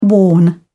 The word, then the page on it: warn: British English